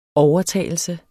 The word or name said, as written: OVERTAGELSEN